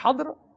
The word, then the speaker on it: خضر: اس سے پہلے یہ بتایا کہ خضر جو ہے اس کے تین جو ہیں وہ تلفظ ہیں، تین پروننسیشنز ہیں اس کی، ایک ہے حضر، دوسری ہے حضر، اور تیسری ہے حضر.